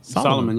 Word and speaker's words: Salomon: And I had on the Salomon. How you pronounce it? – Solomon, yeah.